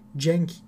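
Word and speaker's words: Dieng: Veya onun yerini alacak olan adam yedekten Gorgi Denk, Cenk diye de okunuyor bazen.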